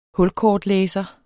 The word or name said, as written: hulkortlæser